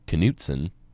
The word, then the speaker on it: Knutson: It's the K and the N, because it's pronounced Knutson, right? It's Scandinavian, or I got some Norwegian in me. But a lot of people think the K is silent,